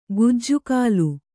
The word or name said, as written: ಕಜ್ಜಗಲಿ